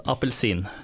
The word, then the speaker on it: appelsin: No, in Norwegian, it's called appelsin.